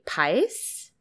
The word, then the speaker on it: Paes: Paes? – That's perfect.